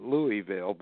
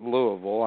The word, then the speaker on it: Louisville: When people read it, they call it Louisville, but you're right. They pronounce it Louisville.